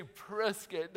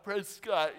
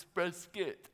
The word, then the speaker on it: Prescott: Say Prescott, not Prescott, even though they spell it like Prescott. It's Prescott, like biscuit.